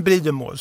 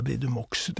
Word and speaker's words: Meaux: då stavas det då M-E-A-U-X som inte hörs. Nej. Nej. Men Bridumå, så eh, det uttalas så. Ja. Man ska nog inte säga Bridumox.